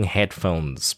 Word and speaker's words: headphone: Noise cancelling headphones built in. Noise cancelling headphones. 然后呢, headphone呢, 很多人都会把那个 head, 那个 D-O 变不见, 就会 headphone.